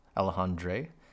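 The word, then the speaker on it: Alexandre: Flores and Sam Raimi and Alejandra himself, Alejandre. Man, I should probably say his name right.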